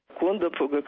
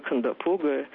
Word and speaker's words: Кондопога: Кондопога, Кондопога. И в этом смысле Дмитрий Горчев, петербургский писатель, высказался наиболее взвешенно. Нужно ставить ударение на последний слог. Вдруг так правильно.